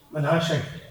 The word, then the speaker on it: Manasseh: Am I saying that right, Brother Manasseh? – Manasseh. – Praise God.